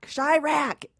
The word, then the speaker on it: Chiraq: Am I pronouncing that wrong? – Chiraq. – It's like Chicago and Duroc. – That's right. – All in one word.